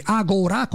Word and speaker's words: Agora: Agora con l'accento sulla A del professor Dorsi,